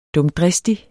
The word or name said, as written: dumdristig